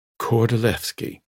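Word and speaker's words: Kordylewski: It looks like Lewski, but it's Levski because the Poles pronounce a W like a V. Kordolewski.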